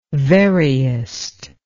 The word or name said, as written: veriest